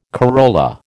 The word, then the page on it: corolla: American English